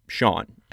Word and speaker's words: Sean: And when I say a different Sean, I'm saying S-H-A-W-N. That's a completely different name. It's not even close or related to Sean, S-E-A-N, which is the right way to spell it.